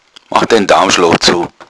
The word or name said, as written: daumschloch